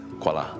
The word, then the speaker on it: Kuala: So the state of Kedah today is spelled K-E-D-A-H. In those days, it's spelled Kedah, Q-U-E-D-A-H. Yeah. I suspect this Q-U-A-I-A is a misspelling of Q-U-A-L-A, which is pronounced as Kuala.